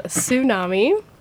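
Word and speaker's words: Sue Nami: My nickname is Tsunami, like a tsunami. It's spelled S-U-E. Space N-A-M-I, and I don't know, I wanted originally to be Helen Wheels, but that one was already taken.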